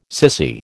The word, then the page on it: sissy: American English